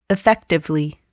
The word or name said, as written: effectively